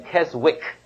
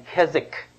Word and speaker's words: Keswick: Keswick 这个字呢，不要读 W 哈，不是念 Keswick 啊，是念 Keswick 啊。英国很多字呢都是奇奇怪怪的发音方法的啊，比方说这个 Gloucester，你看到这个不要念 Gloucester 啊，这个那些 give up 啊，这叫什么？叫做那啊 Worcester 啊 Worcester，不要念 Worcester sauce 啊，Worcester 同样的 Keswick W 是不念的。